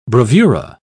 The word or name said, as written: bravura